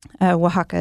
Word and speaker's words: Oaxaca: Oaxaca is spelled phonetically. It's W-A-H-A-K-A.